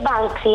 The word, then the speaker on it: Banksy: Si dice Banksy, non si preoccupi.